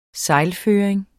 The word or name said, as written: sejlføring